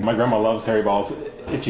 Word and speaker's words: Baals: It also produced some new merchandise because the winner was Harry B-A-A-L-S. He pronounced it balls. Really. The family has since changed the pronunciation to Bales.